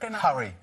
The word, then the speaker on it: Harry: Harry,